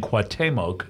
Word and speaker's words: Cuauhtemoc: I'm phonetically saying Cuauhtemoc. Is that the way? – That is correct.